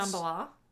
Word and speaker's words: Shambhala: Is it Shambhala? – It is Shambhala, yeah. – Maybe Shambhala is actually the correct way to say it, but Three Dog Night ruined it for us all.